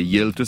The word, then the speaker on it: Hjalte: et Hjelte, ça s'écrit H-J-A-L-T-E.